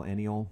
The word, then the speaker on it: Aniol: But you A-N-I-O-L, Eniol.